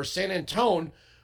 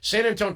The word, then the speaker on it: San Antonio: A large white bus bound for San Antone was, if I'm saying that right, I know there's that's like Louisville, Louisville, San Antonio, San Antonio. San Antonio, Texas was overtaken Monday afternoon during a two and a half hour trip from the International Bridge Del Rio.